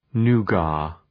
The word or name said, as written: nougat